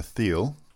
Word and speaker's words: Thiel: There was a very interesting speech on Monday by a man that we've decided should be pronounced Peter Thiel.